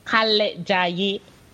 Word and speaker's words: Kalejaiye: Kalejaiye.